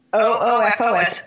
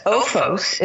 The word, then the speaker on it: OOFOS: OOFOS. OOFOS is how I think it's said.